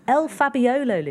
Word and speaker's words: El Fabiolo: I keep putting in an extra syllable. El Fabiolo, Lydia.